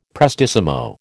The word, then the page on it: prestissimo: American English